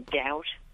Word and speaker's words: doubt: I teach English as a second language, and my students will often ask me why we don't pronounce the B in doubt and debt, mm-hmm.